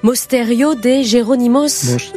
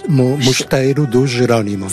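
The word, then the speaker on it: Mosteiro dos Jerónimos: Et quand on parle, donc, je l'ai mal prononcé tout à l'heure, mais Mosteiro de Geronimos. – Mosteiro de Geronimos. – C'est ça.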